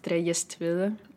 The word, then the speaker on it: Trelles-Tvede: My full name is Sophie Trelles-Tvede, actually pronounced Trelles-Tvede, which is half Spanish, half Danish, so it's pretty much unpronounceable for anyone on this planet, but there you go.